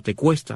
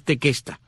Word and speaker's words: Tequesta: Otros indios de esta área son los llamados tequesta o tecuesta, de acuerdo como lo menciones, lo pronuncias al inglés o el español, pero es tequesta, tequesta con Q, T-E-Q-U-E-S-T-A,